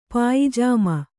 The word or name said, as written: ಪಾಯಿಜಾಮ